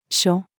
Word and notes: 諸: female

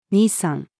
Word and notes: 兄さん: female

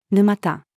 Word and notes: 沼田: female

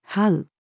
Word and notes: 這う: female

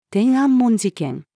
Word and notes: 天安門事件: female

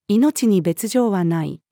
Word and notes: 命に別状はない: female

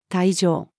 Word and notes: 苔状: female

